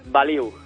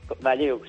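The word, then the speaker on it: Balliu: ¿Pronuncio bien Bayú o en albanés cómo es? – Ellos lo pronuncian más Baliu. – Baliu. Sí, y tú en tu vida normal Bayú, ¿no? – Bayú, sí, correcto, correcto.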